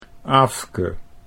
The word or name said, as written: Aafke